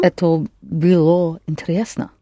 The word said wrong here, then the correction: было: Good, only don't accent the о. – Это было интересно.